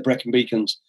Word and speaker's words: Brecon Beacons: And is that, am I saying it right? The Brecon Beacons, is that how you say? – Yeah. It's better known as Black Mountains, but the Brecon Beacons.